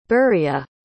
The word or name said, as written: beria